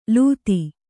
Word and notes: ಲೂತಿ: kannada

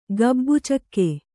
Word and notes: ಗಾಬಿಚುಕ್ಕೆ: gābi cukke

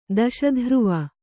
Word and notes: ದಶಧ್ರುವ: kannada

